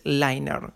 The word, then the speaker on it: Liner: La primera aplicación es Liner. Liner, ¿sí? Liner, que se escribe L-I-N-E-R, ¿sí?